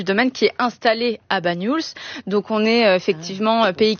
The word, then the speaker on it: Bagnols: Comment on le prononce? – Bagnols. Oui, c'est ça.